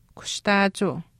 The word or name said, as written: kushtaachuu